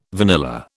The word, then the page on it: vanilla: British English